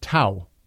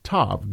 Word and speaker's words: tav: Thus, tav would have been pronounced as tau rather than as tav. Today, most people pronounce the name of this letter as tav because most people, including myself and many professors, follow modern Hebrew pronunciation.